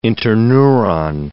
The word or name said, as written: interneuron